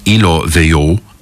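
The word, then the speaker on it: Ilo Veyou: Hey, apropos of delightful Europeans, this is a young lady called Camille from an album called Ilo Vio. And that is how you pronounce it.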